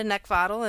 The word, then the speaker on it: Neckvatal: And that you just look me up by my name, which is Brenda Neckvatal, and my last name is spelled N-E-C-K, like the thing you want to choke. V is in Victor, A-T-A-L.